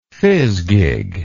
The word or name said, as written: fizgig